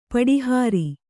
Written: ಪಟಹಾರಿ

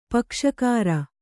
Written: ಪಕ್ಷಕಾರ

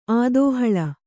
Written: ಆದೋಹಳ

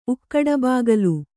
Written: ಉಕ್ಕಡಬಾಗಲು

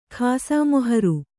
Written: ಖಾಸಮೊಹರು

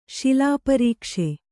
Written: ಶಿಲಾಪರೀಕ್ಷೆ

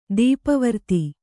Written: ಧೂಪವರ್ತಿ

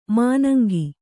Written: ಮಾನಂಗಿ

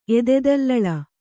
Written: ಎದೆದಲ್ಲಳ

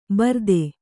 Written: ಬರ್ದೆ

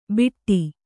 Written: ಬಿಟ್ಟಿ

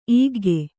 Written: ಈಗ್ಗೆ

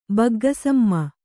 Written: ಬಗ್ಗಸಮ್ಮ

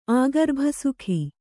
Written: ಆಗರ್ಭಸುಖಿ

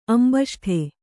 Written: ಅಂಬಷ್ಠ

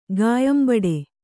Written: ಗಾಯಂಬಡೆ

